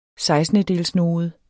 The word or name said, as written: sekstendedelsnode